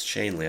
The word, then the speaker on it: Schoenle: Spelled like my German teacher would tell me to pronounce it with umlauts, but it's Shanley, I do believe.